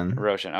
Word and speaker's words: Roshan: Roshan.